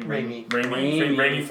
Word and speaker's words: Raimi: How do you say his name? Raimi? – Raimi film.